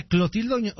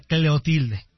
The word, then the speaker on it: Clotilde: Pero era Doña Clotilde o Doña Cleotilde. – Doña Cleotilde. – Era Clotilde, ¿no? Acá es Clotilde. – Ah, pues tal vez era Clotilde y nosotros lo pronunciábamos mal.